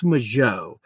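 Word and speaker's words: Mageau: Majoe, I've heard it pronounced a few different ways. I think it's Majoe. It's M-A-G-E-A-U.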